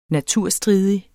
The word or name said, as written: naturstridig